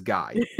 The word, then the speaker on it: Guy: In this province, it is pronounced, it is gee, gee, gee, gee, Cole, Nick, gee, gee, gee, gee. Everywhere else, it is Guy.